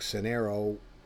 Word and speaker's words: Synereo: They started B-E-A-T-L-E. And this is Synereo, S-Y-N-E-R-E-O. So, and looks like Synereo, but it's pronounced Synereo.